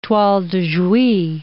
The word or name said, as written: toile de Jouy